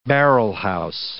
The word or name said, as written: barrelhouse